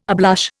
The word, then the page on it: ablush: Indian